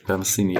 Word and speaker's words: Metheny: Das ist This is North America von David Bowie und, wenn ich es richtig ausspreche, Pat Metheny. – Pat Metheny, ja.